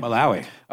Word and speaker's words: Malawi: Malawi? – Malawi.